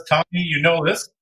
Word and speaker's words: Tommy Yionoulis: Today, we have a very special guest with us, Tommy Yionoulis, who is the founder of Ops Analytica.